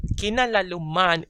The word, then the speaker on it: kinalaluman: So that lalu, the lu turns into a W. So kinalauman instead of kinalaluman.